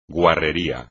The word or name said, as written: guarrería